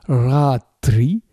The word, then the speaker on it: ratri: Il est au féminin parce qu'il se rapporte au mot suivant, ratris, accusatif pluriel du mot ratri, qui signifie la nuit. C'est ratri, avec la syllabe ra longue.